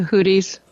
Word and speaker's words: Houthis: But I'm sure you saw this on news that I don't know how to pronounce this word, Houthis. – The Houthis. – Houthis, that's what I thought, but I wasn't sure. – You know, it could be Houthis or the Houthis. Different times, but I say Houthis.